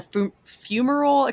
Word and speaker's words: fumarole: You guys were both involved in a fumarole extraction mission. I don't even know if I said that correctly. – Yeah, you said it right.